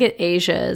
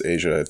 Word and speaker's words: Asa: I think Asia is how you pronounce that name. ASA. – Yeah, I think it's Asia.